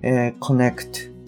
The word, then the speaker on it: connect: で、それから、それに似てるんですけれども、語尾の T っていうのは発音しないケースっていうのが結構多いです。今回出てきた、 connect の T ですね。これは、発音されないケースが多いので、 you can connect ぐらいで止まってしまってる。